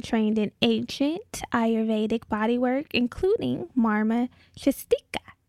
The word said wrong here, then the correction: Chikitsa: Hope I said that right. Chikitsa therapy and foundational Panchakarma therapies.